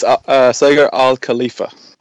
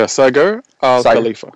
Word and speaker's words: Saqer Al-Khalifa: it's Saqer Al-Khalifa. – Saqer? – Yeah, Saqer Al-Khalifa.